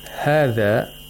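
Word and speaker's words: hadha (هٰذَا): In the word hadha, you'll notice that there is no alif written after the ha of this word. But we still pronounce it like a long vowel. This is one of the letters that are not written but read.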